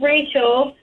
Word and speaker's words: Rachel: Oh, so my name is Rachel, and everywhere I go, people misspell it with an extra A.